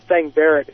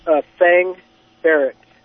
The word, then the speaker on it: Thang Barrett: Thang Barrett. – How do you spell it? – B-A-R-R-E-T-T. And the first name is Thang, T-H-A-N-G. – Thang Barrett.